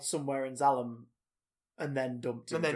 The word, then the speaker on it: Zalem: In the Wikipedia article, it's spelled Z-A-L-E-M. Zalem.